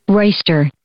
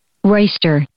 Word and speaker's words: roister: Big A word of the day is Royster. Royster. Royster. – It is spelled R-O-I-S-T-E-R. – Royster.